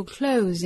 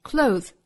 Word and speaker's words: clothes: 后半句的 that 同样可以弱读成 that，和后面的 you 又可以发生连读同化，读成 that you, that you, that you select your clothes every day. Clothes 衣服这个单词读成 clothes, clothes。在末尾呢是两个摩擦音，一个是舌头伸出来一点点的 z，一个是舌头缩回去摩擦齿背的 z。